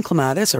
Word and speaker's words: clematis: But the most common clematis, or clematis, as it's properly pronounced, is it's called Jackmanni, which is likely the one you have.